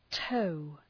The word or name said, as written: tow